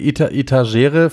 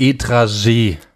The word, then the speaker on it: Etagere: Ich wurde gerügt, dass wir Etagere falsch aussprechen. – Auch, nämlich. – Es heißt Etragee.